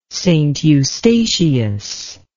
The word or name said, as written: St Eustatius